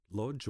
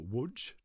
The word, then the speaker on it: Łódź: My parents were Polish Jews, born in Lodz or Łódź, depending on your pronunciation, and they were both Holocaust survivors.